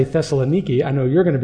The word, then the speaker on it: Thessaloniki: And just a quick disclaimer, they don't pronounce Thessalonica the way we do. They call it Thessaloniki.